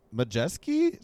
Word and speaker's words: Majeski: Ty Majeski, is that how you say his name? – Yeah, my Wisconsin boy.